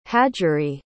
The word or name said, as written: hajari